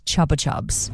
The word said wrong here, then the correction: Chupa Chups: Your Chupa Chup pop without this. – Chupa Chups. Spanish, Megan.